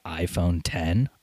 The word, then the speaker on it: iPhone X: Because they got on stage and said it's pronounced the iPhone 10 on stage.